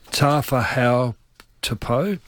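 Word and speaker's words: Tafahau Tupo (Tāufaʻāhau Tupou): I'm going to try and pronounce his name. – Mm-hmm. – Tafahau Tupo? – Correct. – Perfect.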